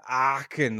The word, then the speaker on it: Aachen: You really got to dig deep in the back of your throat to say that Aachen, like it's really like that. Like, how do you spell that? – It's A-A-C-H-E-N.